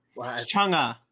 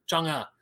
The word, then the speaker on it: Chang'e: Is because she is Zhang-Yi, or Chang'e. – Chang'e.